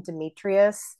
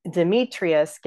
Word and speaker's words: Demetrius: Demetrius gets sort of shoved down from four syllables to three to fit into the sentence.